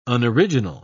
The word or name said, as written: unoriginal